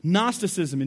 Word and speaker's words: Gnosticism: Gnosticism, and you spell that with G-N-O-S, that's like a gnosis. That's how it begins. G-N-O-S-I-S, like gnosis.